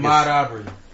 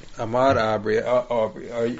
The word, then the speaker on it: Ahmaud Arbery: It seems like Ahmaud Arbery. – Ahmaud Arbery.